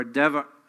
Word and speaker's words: devar: But to the Hebrews, the word or devar, uh, D E B A R, but the B is pronounced like a V, communicates God's divine speaking, as when God speaks in creation and it comes to pass.